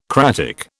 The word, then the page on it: cratic: British English